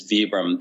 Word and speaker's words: Vibram: If you were traveling in Italy, usually people would pronounce it as Vibram. The founder of the company was a gentleman named Vitali Bramani. – Mm-hmm. – And so in Italy, you would pronounce it like Vibram. Here, I think a lot of people saw the word and read it similar to the way you say vibrate.